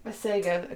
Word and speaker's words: Masego: Masego.